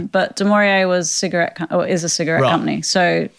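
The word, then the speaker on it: du Maurier: Demoria? – Demoria.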